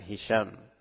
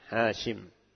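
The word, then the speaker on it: Hashim (هاشم): Ya, sebagian cetakan di ta'liq di situ dikatakan ya, fil matbu, pada sebagian cetakan Hisham. Ini keliru. Ya, yang sahih Hashim.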